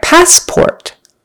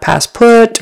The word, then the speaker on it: passport: All right, passport. Passport. First syllable, stressed. Passport, not passport, but passport. And then make sure you get the O-R in port. Passport, not passput or passport. Passport, or rhymes with door.